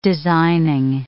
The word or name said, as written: designing